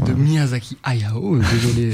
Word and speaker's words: Hayao: Je prononce bien Hayao, c'est... – Oui, tout à fait.